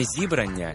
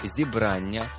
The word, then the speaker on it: зібрання: По-перше, не зібрання і не зібрання, а зібрання – правильний наголос.